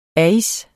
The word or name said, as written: ais